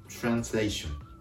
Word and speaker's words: translation: これは L の発音ですから、舌を上顎にしっかりとくっつけて、ラリルエロのレーション、レーションと発音するところがポイントです。続けると、 translation、 translation。この R と L の発音、ごっちゃにならないように気をつけてください。